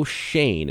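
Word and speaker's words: Schoen: So let's start with the New York Giants really quick here, and Joe Shane, and Shane is how you pronounce it, for the new GM there for the New York Giants.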